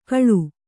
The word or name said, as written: ಕಳು